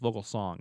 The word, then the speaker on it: Vogelsong: Did I say this is Vogelsong? It's Vogelsong. Did I just pronounce his name wrong? Vogelsong. My bad on that.